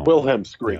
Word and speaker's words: Wilhelm scream: Wilhelm Scream. How do you say it? – Wilhelm Scream.